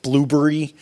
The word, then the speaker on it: Blubrry: If you're not using those, there are a couple tools out there like Blubrry, which I pronounce that way because there are no vowels in the last half of it, and Podtrac, which is what we use.